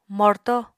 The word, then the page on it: morto: Apatani